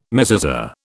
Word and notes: mezuzah: British English

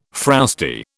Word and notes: frowsty: British English

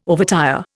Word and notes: overtire: Indian

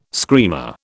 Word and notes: screamer: British English

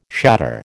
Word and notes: shatter: American English

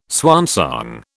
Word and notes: swansong: British English

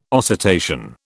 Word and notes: oscitation: British English

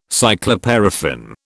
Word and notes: cycloparaffin: British English

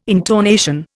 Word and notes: intonation: Indian

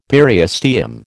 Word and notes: periosteum: American English

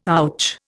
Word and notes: ouch: Indian

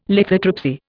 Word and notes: lithotripsy: Indian

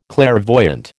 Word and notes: clairvoyant: American English

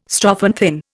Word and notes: strophanthin: Indian

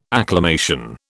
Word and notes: acclamation: British English

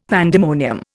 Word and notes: pandemonium: Indian

